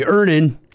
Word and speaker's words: Earnin: Earnin. Earnin. E A R N I N is pronounced Earnin.